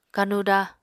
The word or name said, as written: kanuda